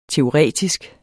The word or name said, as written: teoretisk